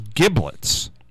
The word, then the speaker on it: giblets: Oh, somebody the other day thought it was giblets, not giblets. – Now it's pronounced as a J, I do believe.